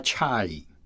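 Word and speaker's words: chai (чай): 他们的茶就叫 chai。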